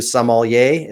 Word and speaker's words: sommelier: Dave, you mentioned that you are a level 2 sommelier. Is I pronounce that correctly? – Yes, I've got my I've forgotten the name of it, the little tin cup, the Vin to something.